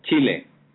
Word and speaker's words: Chile: It's Chile, right? Is that how you pronounce you guys' country? – It's Chile. – Chile. Okay. Right. Not to be that's right.